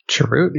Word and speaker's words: Chirrut: Chirrut, yeah.